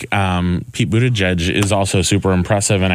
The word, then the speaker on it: Buttigieg: Buttigieg. – Right. Buttigieg.